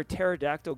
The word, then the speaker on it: pterodactyl: Why can't you hear a pterodactyl going to the bathroom? Because because the P is silent, James.